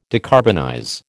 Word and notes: decarbonize: American English

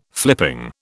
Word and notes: flipping: British English

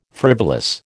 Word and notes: frivolous: American English

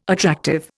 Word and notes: attractive: Indian

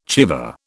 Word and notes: chèvre: British English

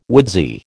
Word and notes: woodsy: American English

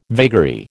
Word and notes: vagary: American English

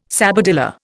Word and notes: sabadilla: Indian

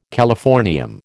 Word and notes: californium: American English

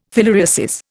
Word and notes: filariasis: Indian

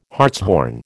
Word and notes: hartshorn: American English

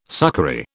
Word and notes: succory: British English

